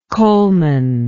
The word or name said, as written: Coleman